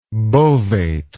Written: bovate

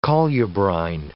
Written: colubrine

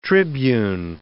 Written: tribune

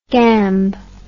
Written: gamb

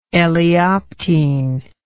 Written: eleoptene

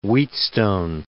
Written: Wheatstone

